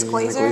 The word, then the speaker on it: Glazer: Or Glazer, how do you pronounce her? Like, I think it's Glazer. – Glazer? – Yeah.